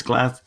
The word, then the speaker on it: Glasgow: It's Glasgow. – It's Glasgow. – Glasgow. Not Glasgow. – Not Glasgow. Glasgow. No. It's like, it isn't Edinburgh either. It's Edinburgh.